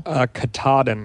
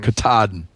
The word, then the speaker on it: Katahdin: Katahdin. – Katahdin.